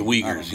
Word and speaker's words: Uyghurs: I believe it's UGH. What is it? Do you know how to spell it? UGH. – Something. – It's Uyghurs, Uyghurs.